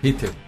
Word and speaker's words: Rittl: Ritchell. Então. Como se tivesse um E que não, não aparece aí.